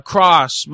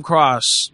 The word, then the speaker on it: Cross: Cross. I'm Cross. My name is Cross. – What's the first name? – Cross. I am Cross. – How do you spell that? – C-R-O-S-S.